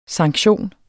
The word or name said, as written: sanktion